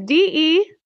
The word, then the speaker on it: DeJonghe: It's DeJonghe.